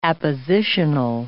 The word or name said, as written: appositional